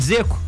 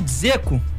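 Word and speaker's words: Džeko: Dzeko? Você fala de Zeco? – Eu falo de Zeco. Não, mas eu sei que é Dzeko corretamente. Eu falo que aqui no Brasil... – Fala de... Então, o Dzeko...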